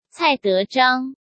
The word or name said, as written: Cai Dezhang